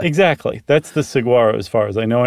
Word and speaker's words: Saguaro: They live in little hollowed out holes of the, how do you say that? Saguaro cacti? – That sounds good.